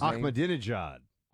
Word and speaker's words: Ahmadinejad: Mahmoud Ahmadinejad or whatever he pronounces his Ahmadinejad.